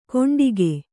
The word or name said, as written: ಕೊಂಡಿಗೆ